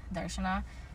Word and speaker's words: Darshana: My name is Darshana, but if you want to say it like hello Indian, it's Darshana.